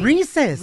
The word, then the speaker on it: Reese's: Reese's.